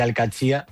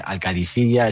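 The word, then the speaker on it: Al-Qadsiah: Bueno, se suele decir Al-Qadsiya, pero también hay gente que lo dice Al-Qadisiya. Es que es difícil, ¿eh? Ah, no es fácil, pero Al-Qadsiya es lo más fácil.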